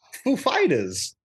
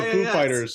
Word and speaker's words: Foo Fighters: And Dave Grohl said that they're the emphasis is he asked, is the emphasis on the Foo or the Fighters? And he, knowing it's Christopher Walken, he said, it's on the Foo, man.